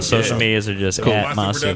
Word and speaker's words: Masue: Masu Productions. – Just Masu Productions. – That's right. – M-A-S-U-E.